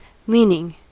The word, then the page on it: leaning: American English